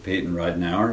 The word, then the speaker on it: Payton Ridenour: I'm here with Payton Ridenour.